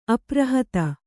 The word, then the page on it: ಅಪ್ರಹತ: aprahata